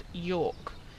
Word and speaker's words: Jork: I think the town you're thinking of is called York, spelled with a J. – Okay. – J-O-R-K.